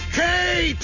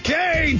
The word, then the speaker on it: Kaye: It's great that my name rhymes with, you know, so many words. because Mark Kaye, two hours a day. Hip hip hooray.